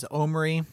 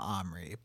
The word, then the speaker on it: Omri: So, for starters, the pronunciation that I found most frequently was Omri. – Oh, sure. Okay. – Not Omri. But there is, I think there are a lot of schools of thought as far as how this kid's name is pronounced.